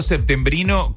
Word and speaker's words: septembrino: El derivado septembrino conserva siempre la P, tanto en la pronunciación como en la escritura, independientemente de la variante que se use para el mes.